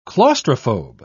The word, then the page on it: claustrophobe: British English